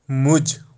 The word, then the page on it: muj: Kashmiri